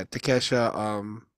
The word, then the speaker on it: Takeshita: Yeah, Takeshita.